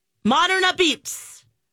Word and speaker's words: Moderna Pizza: But it says if you're local, you know that it's pronounced a beats. Modern a beats.